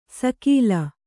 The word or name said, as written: ಸಕೀಲ